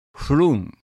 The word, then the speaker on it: frown: 次の行の froon も toon に音を合わせたものですが、 frown が froon と発音されます。